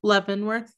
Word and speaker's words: Leavenworth: He was in, how do you say that? Is it Leavenworth or Leavenworth? – Leavenworth.